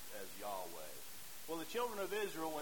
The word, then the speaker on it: Yahweh: We're not sure we're pronouncing it correctly. But for the most part, historically, we think it would have been pronounced as Yahweh.